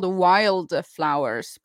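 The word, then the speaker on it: Wylde Flowers: What is It's called Wild Flowers, but it's spelled like WYLD.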